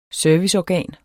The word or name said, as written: SERVICEORGAN